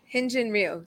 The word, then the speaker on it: Hyunjin Ryu: Hyunjin Ryu.